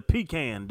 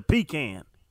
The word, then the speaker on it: pecan: This whole pecan with that long-ass A, let me tell you, it's a pecan, Doug. It's a pecan. – Hmm. What about butter pecan ice cream? Is it butter pecan ice cream? – No, I think pronounced that correctly.